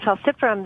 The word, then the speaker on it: falciparum: I mean, when we say it's designed to combat malaria, it's specifically going after the very, very virulent form of the parasite, the Plasmodium, excuse my pronunciation, falciparum? – Yeah, the Plasmodium falciparum, that's right.